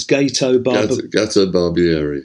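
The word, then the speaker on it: Gato Barbieri: Gatto Barbieri.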